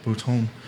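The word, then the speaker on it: Botton: Then, of course, there's Alan D. Bottom, is that how he pronounced his name? – Bouton.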